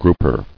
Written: Grouper

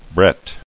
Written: Brett

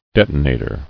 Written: Detonator

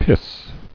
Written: Piss

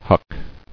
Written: Huck

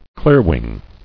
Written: Clearwing

